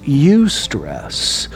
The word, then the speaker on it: eustress: The other type of stress is pronounced eustress.